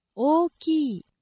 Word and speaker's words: 大きい: It is four syllables. I know it only sounds like two. It sounds like 大きい, two syllables, but it's actually O-O-Ki-I. It is two lengthened syllables. If you do not lengthen them, it will sound wrong in Japanese. O-Ki, O-Ki, okay? Not O-Ki, and not O-Ki, and not O-Ki. It's O-Ki.